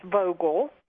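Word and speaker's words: Vogtle: And of course, that's in Georgia, where Vogel, it's pronounced Vogel, is one of the pair of reactors that is under construction, the other being in South Carolina.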